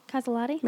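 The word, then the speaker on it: Caselotti: Yeah, her name is Adriana Casalotti. Did I say that right? Casalotti? – Yeah, Casalotti.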